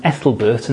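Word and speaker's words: Æthelberht: I think that's pronounced Ethelbert, and that is the correct pronunciation, I have checked it carefully,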